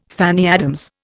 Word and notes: Fanny Adams: Indian

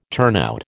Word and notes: turnout: American English

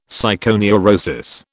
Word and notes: psychoneurosis: British English

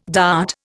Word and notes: dart: Indian

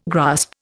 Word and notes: grasp: Indian

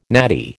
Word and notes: natty: American English

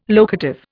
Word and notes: locative: Indian

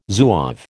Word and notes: Zouave: American English

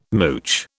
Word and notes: mooch: British English

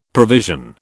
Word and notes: provision: British English

